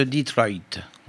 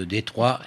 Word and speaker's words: Detroit: Alors, rien à voir avec Benny Carter, mais c'est quand même une musicienne noire américaine de la région de Detroit, ou de Détroit, si on veut le prononcer à la française.